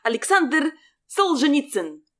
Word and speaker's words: Alexander Solzhenitsyn: Alexander Solzhenitsyn.